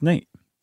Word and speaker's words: Nat: Yeah, it's Life and Adventures of Nat Love, and it's spelled N-A-T and is on the autobiography title. – I swear it's Nate. – Well, I'm looking at the book cover. – I know. I'm telling you, it's pronounced Nate. – Okay, but there is no E.